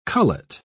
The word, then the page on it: cullet: British English